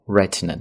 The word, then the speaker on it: Retina: 那么苹果更新后的，注意这个单词读作 Retina， HD 那么就是高清的意思， Retina 视网膜的意思。那么苹果的手机屏呢叫做视网膜屏，所以呢它叫做 Retina displays。